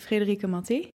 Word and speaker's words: Frederica Matte: I would pronounce it Frederica Matte. – Mati, I knew there was like a trick. – But then I've also been informed that my last name is apparently Italian, so I'm probably butchering it myself every Mati.